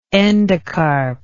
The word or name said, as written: endocarpic